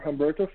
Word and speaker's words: Humberto: I'm under Humberto Farias. My first name is spelled H-U-M-B-E-R.